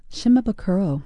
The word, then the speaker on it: Shimabukuro: Shimabukuro.